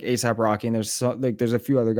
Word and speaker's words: ASAP Rocky: but is it ASAP Rocky? – ASAP Rocky. – It's ASAP Rocky.